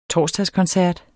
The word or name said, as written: torsdagskoncert